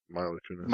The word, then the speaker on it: Mila Kunis: Yeah, Mila Kunis.